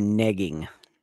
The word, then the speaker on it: negging: You weren't nagging, you were negging. – Nigging. – You gotta pronounce it right, because nagging is a whole nother fucking animal.